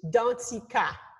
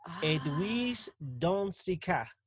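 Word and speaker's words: Danticat: So is it Dundecat? – It's, I'll tell you my full name as my mama would say it. – Okay. Like you're about to get in trouble. – Yeah. It's Edwige Dantica. – Edwige Dantica. – Yes, the T is silent. – Oh.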